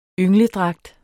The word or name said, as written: yngledragt